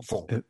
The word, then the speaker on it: Fung: Fuang, no sé cómo se pronuncia, F-U-N-G. – F-U-N-G. – Yo que soy medio de Hong Kong, Fuang.